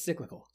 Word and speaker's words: cyclical: Please tell me that's how you say it. That's how you say it's cyclical.